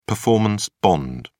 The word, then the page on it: performance bond: British English